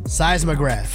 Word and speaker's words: seismograph: Seismograph.